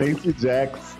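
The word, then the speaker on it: Stacee Jaxx: do Jace Stax. – Stacy Jax. É, Stacy Jax, a gente trocando a sílabas toda.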